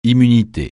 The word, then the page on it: immunité: francais